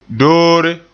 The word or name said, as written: doore